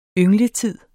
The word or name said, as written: yngletid